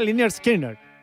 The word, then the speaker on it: Lynyrd Skynyrd: Mira, se pronuncia Lynyrd Skynyrd.